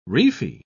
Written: reefy

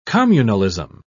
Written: communalism

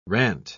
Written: rant

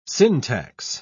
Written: syntax

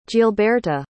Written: gilberta

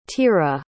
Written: Tira

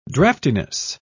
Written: draftiness

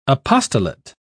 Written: apostolate

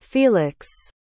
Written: feliks

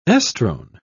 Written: estrone

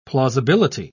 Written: plausibility

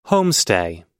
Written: homestay

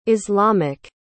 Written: islamic

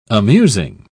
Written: amusing

